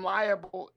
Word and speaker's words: libel: I will say that the Russell Westbrook slander and libel is... – And libel? – It's ridiculous. – The libel? – Yes. Libel. I'm sorry. I put an extra syllable in there.